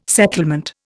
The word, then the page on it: settlement: Indian